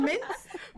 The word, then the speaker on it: mince: I can slice, dice, and manice. – And manice. You mean mince? – Mince. It's spelled manice.